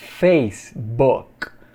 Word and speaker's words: Facebook: Aqui no, aqui no Brasil a gente fala Facebook, but in English, Facebook. Repeat. Facebook. O casinho é mudo, esse K é mudo.